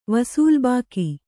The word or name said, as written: ವಸೂಲ್‍ಬಾಕಿ